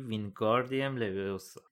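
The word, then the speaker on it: Wingardium Leviosa: بده هرماینی با اوقات تلخی گفت داری اشتباه تلفظ میکنی باید بگی وینگاردیم لویوسا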